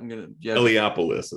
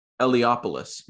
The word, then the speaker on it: Eliopoulos: Eliopoulos is how I pronounce it. – Eliopoulos.